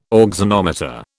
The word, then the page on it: auxanometer: British English